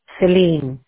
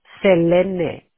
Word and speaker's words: Selene: Celine. – Could you spell it? It is actually Selene, S-E-L-E-N-E. Here in the U.S., everybody calls me Celine, so.